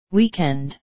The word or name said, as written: weekend